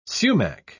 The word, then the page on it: sumach: British English